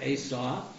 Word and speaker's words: Esau: This says ESAU, which I've always heard pronounced Esau. – Right. – Or Esau. – Right. – The Hebrew is not Esau. It's right. – Esau.